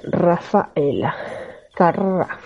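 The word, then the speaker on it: Raffaella Carrà: que pronunciación en italiano es Rafaela Carrà.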